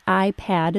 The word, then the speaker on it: iPad: The words iPad and iPod are identical except that iPad is pronounced with a short A and iPod is pronounced with a short O.